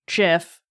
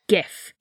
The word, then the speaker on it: GIF: Each were just seconds long, so pretty much like a GIF or JIF, depending on... – It is GIF. – It's a GIF. It's a hard G. The guy who invented it has said that, so...